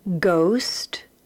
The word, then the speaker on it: ghost: Second column, ghost, ghastly, ghetto, ghoul. And the third column, rhyme, rhymed, rhyming. And again, all of those words have the letter H, but that H is silent.